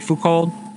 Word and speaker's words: Foucault: Am I saying that right? – Foucault. Yeah. – Drop the T.